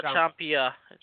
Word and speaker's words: Ciampa: Not Ciampa. – Oh, yeah. Ciampa. Tommaso Ciampa. – Yeah, there you go. – Okay. Uh-huh. So I don't want to say that Gargano will turn on Ciampa because of the tease that we got, but that's been months ago.